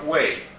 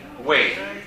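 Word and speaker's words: Wei (位): Questo è un altro carattere, un altro classificatore che si pronuncia Wei. Wei, W-E-I. – Quale sarebbe il quarto? Ed è Wei, W-E-I, quarto d'ora.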